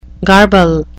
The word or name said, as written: garble